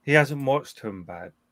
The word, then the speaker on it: Tumbbad: It looks like Tumbad, but I think it's pronounced Tumba.